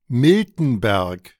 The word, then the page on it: Miltenberg: [ˈmɪltn̩ˌbɛʁk]; German